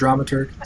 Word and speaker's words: dramaturg: Is that how you say that word, dramaturg? Is that right? – That is how you say the word.